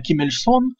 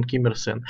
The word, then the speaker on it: Ким Иль Сон: Да, это интересная поправка, что Ким Ир Сен вообще-то Ким Иль Сон, но есть такое правило, что устоявшиеся названия не меняются.